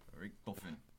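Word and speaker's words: Richthofen: Richtofen.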